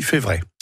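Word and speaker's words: Febvret: Alors, la prononciation, déjà, on dit Févré. – Comme s'il n'y avait pas de B.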